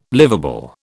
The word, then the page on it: livable: British English